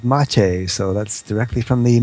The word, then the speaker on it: mate: The pronunciation apparently is mate from the Spanish.